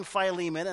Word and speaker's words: Philemon: I actually went to the Greek New Testament and looked at it, and I went, well, the E is definitely a long E, but that I is debatable of whether that's a short I or a long I. So be that as it may, I grew up saying Philemon, and that's exactly what I'm going to say this morning.